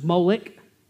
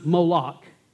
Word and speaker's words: Molech: So sometimes it's pronounced Molech, sometimes Moloch, but the name is spelled MLK in the Hebrew language.